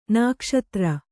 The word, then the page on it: ನಾಗಾಸ್ತ್ರ: nāgāstra